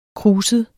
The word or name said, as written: kruset